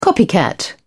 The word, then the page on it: copycat: British English